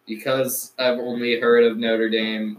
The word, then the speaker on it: Notre Dame: And you said it wrong. It's Notre Dame. Hunchback of Notre Dame.